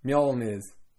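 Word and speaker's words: Mjölnir: Well, how do you say it? – Mjolnir.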